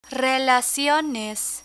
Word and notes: relaciones: Spanish

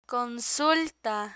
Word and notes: consulta: Spanish

